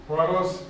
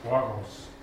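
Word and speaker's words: Horus (ὅρος): Horus ist also so zu transkribieren, anlautende, äh, anlautendes H, Spiritus Asper, Betonung daneben, das Ganze über einen Omikron, dann Rho, Omikron, rundes Sigma am Schluss, Horus.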